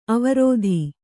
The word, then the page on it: ಅವಿರೋಧ: Kannada